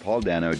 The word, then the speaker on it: Dano: Paul Dano did such a good job. I believe it's pronounced Dano, not Dano, but if I'm wrong, fuck it, I don't care.